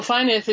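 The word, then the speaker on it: Fynyth: Well, Fineth is Welsh.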